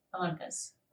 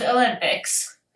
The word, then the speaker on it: Olympus: Olympus. Mount Olympics.